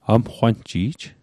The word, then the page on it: amqwanji'j: Mi'gmaq